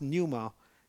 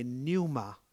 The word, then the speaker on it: pneuma: That's pronounced pneuma, P-N-U-M-A, pneuma.